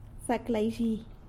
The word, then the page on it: Zac laizhi: sanlucasquiavini